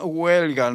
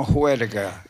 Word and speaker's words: huelga: Mira, se pronuncia huelga, no juerga.